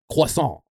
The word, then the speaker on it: croissant: So like the French word is pronounced like croissant. It's like a very French pronunciation.